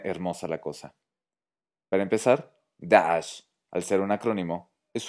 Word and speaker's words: Daesh: Bueno, pues en 2013, el activista sirio Khaled al-Hajj Saleh creó el acrónimo Daesh, que en árabe se pronuncia Daesh.